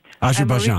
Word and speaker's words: Azerbaijan: And you're from, is it Azerbaijan? Is that how you say it? – That's right. – Azerbaijan.